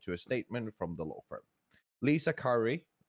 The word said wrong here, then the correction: Corey: I'm probably butchering your last name, Lisa. – Corey.